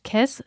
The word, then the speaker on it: Caisse: Das französische Wort Caisse spricht man ähnlich wie Käse aus, also könnte drei Käse hoch jemanden beschreiben, der ja so groß ist wie drei Kisten.